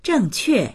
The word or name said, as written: zheng4que4